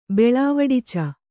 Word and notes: ಬೆಳವಡಿಚ: Kannada

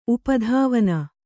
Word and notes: ಉಪಧಾವನ: Kannada

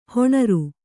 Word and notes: ಹೊಣರು: hoṇaru

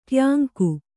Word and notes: ಟ್ಯಾಂಕು: tyāŋku

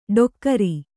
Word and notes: ಡೊಕ್ಕರಿ: ḍokkari

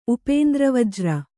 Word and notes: ಉಪೇಂದ್ರವಜ್ರ: upēndra vajra

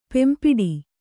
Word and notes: ಪೆಂಪಿಡಿ: kannada

